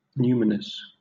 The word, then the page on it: numinous: British English